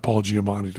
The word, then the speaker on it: Paul Giamatti: They got Paul Giamatti to come in. – Yeah. – Because am I saying his name right? – You are.